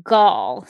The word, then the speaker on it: Gal: Yeah, I think correctly it's Gal. So think of it as, like, I guess G-A-L-L, or maybe you could say G-A-U-L is how you maybe would pronounce it.